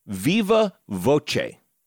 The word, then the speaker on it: viva voce: Because listener Christian Brady posted on Facebook that the word is pronounced Viva Voce. – Mm-hmm. – That's how Christian pronounce it.